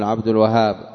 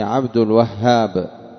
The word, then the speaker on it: عبد الوهاب: والوهاب مثال مبالغة من الواهب، وهو كثير الهبات، وهاب، والهاء فيه مشددة، ويخطئ بعض الناس في نطقها غير مشددة فيقول عبد الوهاب، إنما هي عبد الوهاب.